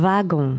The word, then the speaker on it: wagon: The W in this word is pronounced like a V. – That's right, yeah. – So it's a wagon.